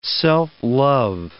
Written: self-love

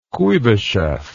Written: kuibyshev

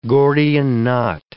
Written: Gordian knot

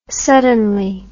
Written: suddenly

